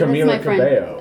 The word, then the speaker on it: Camila Cabello: Please say her name right. – I am. – You did. – Camila Cabello.